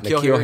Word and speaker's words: N'Keal: So when they took N'Keal, I think I'm saying his name right, N'Keal Harry.